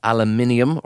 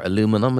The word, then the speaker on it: aluminium: And now that sign was made from plywood, but the new sign has been crafted from powder-coated aluminium, or aluminum as the Americans say, and supported by galvanised steel.